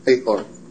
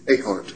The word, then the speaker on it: Ahart: A. Hart. A. Hart. – A. Hart. How do you spell that? – A-H-A-R-T.